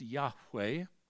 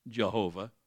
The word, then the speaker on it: Yahweh: Lord is a title, this is his proper name, Yahweh, Y-H-W-H, pronounced Yahweh, not Jehovah.